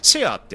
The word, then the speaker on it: SHURE: マイクはね、なんと、なんとですね、ヒカキン、あの、あの YouTuber の、あの、最大手、ヒカキンさんもね、使っている、シェアさん、えー、 SHURE って書いて、シェアって読むんですけど、あの、あ、よ読むのかなどうなんだろうそそれ教えてほしいんですけど、シェアっていうね、